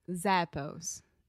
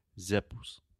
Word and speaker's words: Zappos: So, a normal Brazilian without pronunciation training would probably say zippos, zippos, something like that. But it's zappos. Zappos.